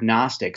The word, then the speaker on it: Gnostic: And, you know, as a lot of people know, the word Gnostic spelled with a G, right? So the silent G in Gnostic is the same as our silent K in the word knowledge, because gnosis means knowledge.